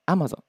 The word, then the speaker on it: Amazon: 発音があですね。 Amazon、 Amazon って言った低いところ、低いところから入りますけど、 Amazon ですね。